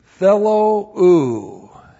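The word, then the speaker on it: Thello u (θέλω): T-H-E-L long O, and then u looks like that in the Greek and the English. Thello u.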